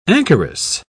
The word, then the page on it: anchoress: British English